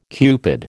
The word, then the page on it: Cupid: American English